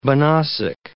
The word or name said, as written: banausic